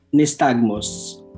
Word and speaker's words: nystagmus: Tama ba yung pronunciation ko? Nystagmus? – Yes, correct.